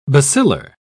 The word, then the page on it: bacillar: British English